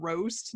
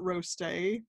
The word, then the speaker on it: Roste: I think it's actually called Roast, not Roste, but it's spelled like R-O-S-T-E, that chocolate house that I told you about.